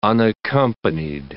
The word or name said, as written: unaccompanied